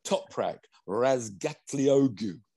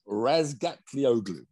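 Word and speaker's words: Razgatlıoğlu: Toprak Razgatlioglu. – I'm glad you... – Oh, I nearly got it in one. – I know that. Very good. Your pronunciation is very good at different names. – Razgatlioglu.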